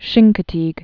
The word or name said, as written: Chincoteague Bay